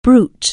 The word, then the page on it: brute: anglais